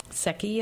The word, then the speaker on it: Secchi: And Secchi is pronounced like Becky, S-E-C-C-H-I.